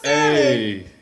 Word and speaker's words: salmon: Now I know that this is about the tweet, but did you just pronounce the L? – I didn't even catch that. – Y'all want to talk about bitches who like to cook salmon with the lemon slices?